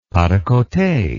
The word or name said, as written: pas de cote